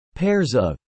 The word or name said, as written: pairs of